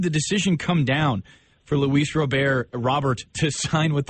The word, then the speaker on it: Luis Robert: So, but I've always heard as Luis Robert. – Well, then we will go with Luis Robert until, I guess, Luis tells us differently.